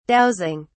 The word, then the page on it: dowsing: British English